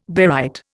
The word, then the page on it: barite: Indian